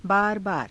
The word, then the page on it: baarabaara: Hindi